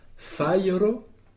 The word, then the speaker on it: fajro: Alors, fairo, ça s'écrit F-A-J-R-O. Fai, c'est une syllabe. Et donc, on n'accentue pas le J, on accentue fai en ensemble. C'est une syllabe. Et évidemment, la dernière syllabe est atténuée.